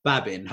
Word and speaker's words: Babin: Well, today it's really nice to be joined by Nicolas Babin.